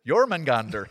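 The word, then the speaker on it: Jörmungandr: It's Jormungandr. You got to say it with the accent too.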